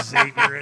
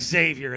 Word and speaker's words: Xavier: Hey, at least you pronounced it properly. – Oh, good. Okay. All right. – A lot of guys will call it Xavier, and that drives me nuts.